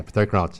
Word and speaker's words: Peter Crouch: Peter Crouch.